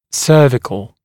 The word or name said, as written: cervical